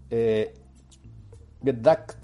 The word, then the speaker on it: Get Ducked: Get Ducked sería la pronunciación exacta, para que lo encuentren cuando lo busquen en el buscador, valga la redundancia, de Amazon, se escribe Ducked, D-U-K-E-D, Get como Get, G-E-T.